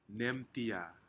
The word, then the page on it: nemtia: Aneityum